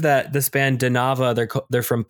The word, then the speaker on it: Danava: It's D A N like Dan A V A, and I believe most people would pronounce that Danava.